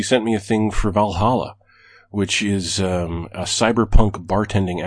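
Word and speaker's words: VA-11 Hall-A: Okay, so it's spelled VA-11 space Hall space or dash A.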